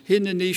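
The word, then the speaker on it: Hineni (הנני): Hinnaini in chapter 6 is pronounced slightly differently, and it's not stressed in the same way as in the other three cases. Hineni shlacheni, the stress is on send me.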